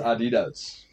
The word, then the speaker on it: Adidas: Pronounced Adidas.